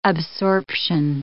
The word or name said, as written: absorption